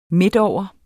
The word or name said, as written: midtover